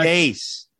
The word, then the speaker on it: Deace: It's it's Dace, by the way. – I knew it. – Half the family pronounces it Dace, so I could be wrong. I really don't know. The Truth be told, but uh, Dace.